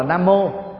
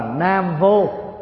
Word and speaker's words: Nam Mô: Còn nếu mà học chữ Hán thông thường, người ta không đọc là Nam Mô mà đọc là Nam Vô, thì lúc đó có nghĩa là gì? Ở miền Nam hay là ông Nam đó không có cái gì đó. Chứ vô đây là phủ định từ.